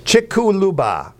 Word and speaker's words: Chicxulub: Chick-chu-lub. Chick-chu-lub. Chick-chu-lub. – Chick-chu-lub. – C-H-I-C-X-U-L-U-B.